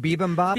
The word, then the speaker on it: bibimbap: Is that the right way to say it? Bibimbap? – Bibimbap. Yep.